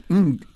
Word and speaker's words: Ng: And the singer Jessica, and her surname is spelled N-G, and you pronounce it Ng.